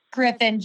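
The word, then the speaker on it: Gryffin: Our sons are 22 and 32, and they also very much like Griffith. Did I say that right? – Griffin, I think it's G-R-Y, and how do you spell it? Let me look. It's G-R-Y-F-F-I-N.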